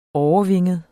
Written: årevinget

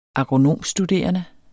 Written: agronomstuderende

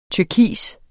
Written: turkis

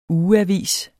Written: ugeavis

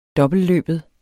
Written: dobbeltløbet

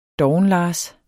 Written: dovenlars

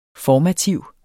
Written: FORMATIV

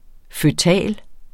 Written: føtal

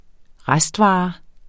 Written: restvare

